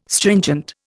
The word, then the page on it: stringent: Indian